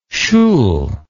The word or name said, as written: schul